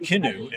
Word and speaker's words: Kinoo: So go to our website, Kinoo. And by the way, it's spelled K-I-N-O-O. And it's spelled that way because kin is in kinship, which is family. So kin.